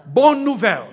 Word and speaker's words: bonne nouvelle: They have a phrase that they use for good news, and that term is bonnes nouvelles. Did I say it right? Bonne nouvelle. Right.